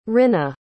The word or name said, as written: rinna